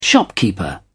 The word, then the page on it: shopkeeper: British English